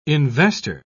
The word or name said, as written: investor